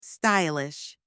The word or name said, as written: stylish